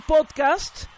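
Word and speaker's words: pódcast: Entonces, podcast se escribe con una tilde en la O. Podcast. – En español. Acuérdense que la pronunciamos como una palabra grave.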